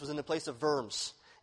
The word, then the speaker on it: Worms: If you see it spelled out, by the way, in history books, it makes you laugh because it looks like diet of worms. But it's really diet of Worms is how you pronounce that.